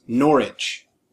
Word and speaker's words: Norwich: Like he lived in a town called that was spelt Norwich, but it's pronounced Norwich.